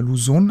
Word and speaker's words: Luzon: Die Clark Airways Base ist ein früherer Luftwaffenstützpunkt der US Air Force auf der philippinischen Insel Luzon oder Luzon ausgesprochen.